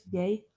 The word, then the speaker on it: Ye: Instead of Kanye, now it's just Ye.